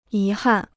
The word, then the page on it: 遗憾: yí hàn